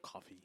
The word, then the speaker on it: Kofi: It looks like Kofi. – Coffee.